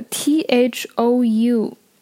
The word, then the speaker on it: thou: 那么我们看这个 t h o u 这个词呢，其实读作 thou，呃，那么 thou 呢其实是第二人称的主格形式 you y o u。